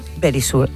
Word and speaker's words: Verisure: Pero Verisure, ay, que me lo dice, eso es.